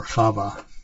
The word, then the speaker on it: Chava: Anyway, um, so let's go back and we, we talked about uh, what was offered to Eve, okay, or Chava, as it's, as it's pronounced in, in um, Hebrew.